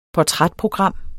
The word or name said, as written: portrætprogram